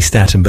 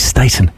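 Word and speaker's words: Staton: For years, I was calling her Candy Staten, but I heard her in an interview a few years ago, and she's Staten, Candy Staten. That's how she pronounces it, so we'll stick with that.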